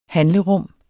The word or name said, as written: handlerum